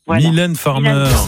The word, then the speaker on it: Mylène Farmer: Mineur Farmer. Je prononce mal. – Alors, prononcez-le moi bien, sinon je ne peux pas l'accorder. – Mylène Farmer.